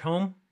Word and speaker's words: Homme: Josh Homme.